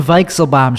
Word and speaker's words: Weichselbaum: It's the Tim Weichselbaum Show. That's how you say it. V. It's a V sound in German.